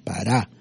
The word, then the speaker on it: Pará: Anatot era el pueblo donde venía Jeremías y había un pequeño fuente que se llamaba Pará, con acento en la última A, Pará.